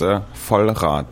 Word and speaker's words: Vollrath: Pronounce it first. – It's German, and it's Vollrath.